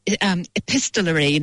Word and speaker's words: epistolary: And they're both of those books are set in the same orphanage, and they're both written as epistolary novels. Fun word. – Really struggle to pronounce that. So well done you.